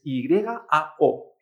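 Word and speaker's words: yao (要): El verbo querer es Y a O en cuarto tono. Pues sustituimos mentalmente esa Y por una I latina. Entonces esto se pronuncia yao.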